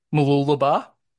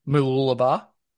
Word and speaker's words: Mooloolaba: Mooloolaba. – Pretty good. Mooloolaba. – Okay. Well, how do you say it again? – Mooloolaba.